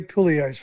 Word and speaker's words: Puleio: I hope I got that pronunciation right, Daz. How are you, mate? And welcome along. – Yeah, good. Thanks, James. Thanks very much. Hey, is it Puleio? Yeah, Puleio's fine. Yep.